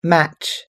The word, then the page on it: match: American English